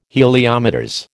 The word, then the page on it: Heliometers: American English